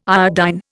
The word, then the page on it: iodine: Indian